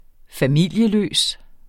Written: familieløs

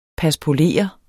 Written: paspolere

